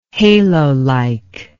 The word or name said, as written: halolike